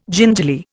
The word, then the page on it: gingerly: Indian